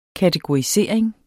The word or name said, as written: kategorisering